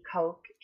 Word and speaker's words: Koch: So it's my first name and last name, Brittany Koch, K-O-C-H, and then Brittany is spelled B-R-I-T-N-E-Y.